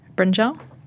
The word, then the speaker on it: brinjal: Brinjal.